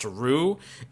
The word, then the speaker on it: Ryu: He's like, he also wanted me to call out Kevin again for how Street Fighter character Ryu's name is actually pronounced Rue, and just like the first time that he made this call out, Andy, no one will ever call that character Rue.